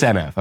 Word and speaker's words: NF: Am I pronouncing that right or is it pronounced oomph? It's NF. Okay. It's not oomph, oomph, oomph.